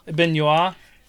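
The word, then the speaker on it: Benoît: You pronounce it Philippon? Benoit. – Benoit. – Like, okay. Like Benoit. Like the Arthur joke.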